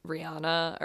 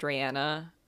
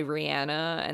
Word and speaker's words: Rihanna: and it's literally just Umbrella by Rihanna, or I think she wants it to be pronounced Rihanna, so let me correct myself. It's Umbrella by Rihanna, and that's the only song that's on it.